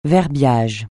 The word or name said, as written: verbiage